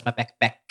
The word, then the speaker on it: Mapakpak: My last name is Mapakpak. Or if you have an accent, it's pronounced as Mapekpek.